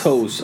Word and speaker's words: Taos: Toss. – Is that how you say it? Toss? – Toes. I guess toes. – Okay. Whatever. It's T-A-O-S.